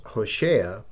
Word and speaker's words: Hoshea: And his name is properly pronounced Hoshea, which means salvation. And the English pronunciation, it leans more toward the Hispanic term of Jose, but it adds an A on the end, thus being Hosea.